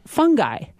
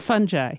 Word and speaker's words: fungi: I've always called it candida, and I've always called it fungi. So, two new pronunciations. Thank you. Candida and fungi.